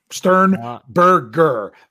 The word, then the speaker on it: Sternberger: Sternberger.